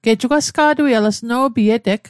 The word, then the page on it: sno'pi: Mi'gmaq